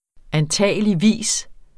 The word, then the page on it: antageligvis: anˈtæˀjəliˈviˀs; anˈtæˀjəliˌviˀs